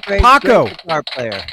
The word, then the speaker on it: Paco: you know, Paco rhymes with taco.